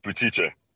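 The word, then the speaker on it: Buttice: Yeah, the American pronunciation butchers the hell out of that one. – Oh, yeah, that's fucked up. How do you say it in Italian? – Butice.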